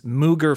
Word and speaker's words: Moog: It's spelled M-O-O-G, but the man's name is Robert Moog, the one who invented it. – I know that it's Moog, but when I see, like, just Moog by itself, M-O-O-G, I can accept Moog. Like, my brain automatically knows it's pronounced Moog.